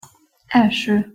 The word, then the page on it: elso: Hungarian